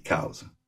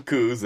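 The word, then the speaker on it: Coues: And the bottom line is Elliot Cowes, in a publication in 1882, has a little footnote where he describes how he pronounces his last name, because there's some other subspecies that are named after him. And he writes C-O-W-Z, cows.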